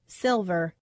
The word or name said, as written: silver